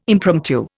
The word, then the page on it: impromptu: Indian